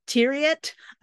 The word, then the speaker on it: Thieriot: Next, we're going to talk about the new series from CBS starring Max Thieriot. I learned how to say his name. Hello. Um, it's chair. It sounds like chariot, but it's mm.